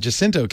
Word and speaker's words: San Jacinto: I pronounce it Jacinto, but what do I know? – Now, of course, the Spanish pronunciation would be San Jacinto, but I don't.